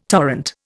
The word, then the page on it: torrent: Indian